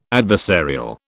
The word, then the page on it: adversarial: British English